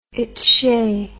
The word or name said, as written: itshay